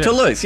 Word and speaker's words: Toulouse: Toulouse, yeah, I said it right ninth time.